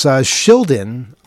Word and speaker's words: Shildon: Yes, Shildon from Level 9.